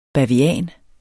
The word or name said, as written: bavian